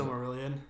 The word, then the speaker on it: Silmarillion: And I've started reading that book too, the Silmarillion, or however you pronounce it. – Silmarillion.